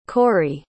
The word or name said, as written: correy